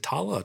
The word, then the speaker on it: Tala: Is it Tala or Tarla? Tala? – Let's see. Tala. T-A-L-A. Tala. I know everyone has kind of a British accent, so it sounds, they could be saying Tarla and pronounce it Tala for all we know. But yeah, it's in the credits as Tala.